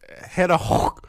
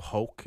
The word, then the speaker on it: Höch: It's Hannah Hoch. It's German. It's spelled, you know, Hannah, H-A-N-N-A-H, and then it's spelled Hoch, like H-O-C-H. But it's pronounced like Hoch or something like Hoch.